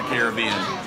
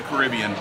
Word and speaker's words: Caribbean: The decor will echo a tropical beachfront home with coral patterns and kelp filigree, there's a big word for you, that celebrates the Caribbean or the Caribbean, depending on how you say it.